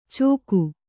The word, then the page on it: ಚೋಖು: Kannada